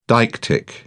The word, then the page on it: deictic: British English